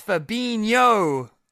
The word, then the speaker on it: Fabinho: No, seriously, it's Fabinho. – I hear that. It's just because that you got Ronaldinho, which is spelt how it's said. – Yeah. – Fabinho. There's nothing in his name that suggests it should be Fabinho.